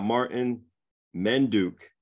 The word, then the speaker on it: Martyn Mendyuk: We got Martyn Mendyuk on Behind the Brand.